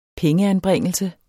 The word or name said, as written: pengeanbringelse